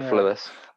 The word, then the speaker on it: Thewlis: I would do David Thewlis, or Thewlis, how do you say it? You know, the guy who plays like Lupin and shit. – Yeah, David Thewlis.